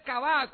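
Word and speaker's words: kavá (קָוָה): Pero los que esperan, el verbo hebreo es kavá, con acento en la última A.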